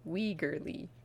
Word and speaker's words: Wegerle: It's a tough name. It looks tougher than it is. It's like W-E-G-E-R-L-E, Weigerly. But that's what YouTube said how to pronounce it, so I believe them.